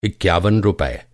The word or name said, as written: इक्यावन